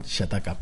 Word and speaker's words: chataca: Mira, en, un en una entrevista que me hicieron en, mm, Siempre lo digo mal, lo del chacata. ¿Cómo? Chataca. – Chataca, ¿ves? Siempre lo digo al revés.